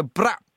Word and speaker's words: brap: Can you just try and go brap? – Try. – It's just a noise. If you just go brap. – Brack. – And brap, so B-R-A-P.